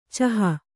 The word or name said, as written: ಚೋಹ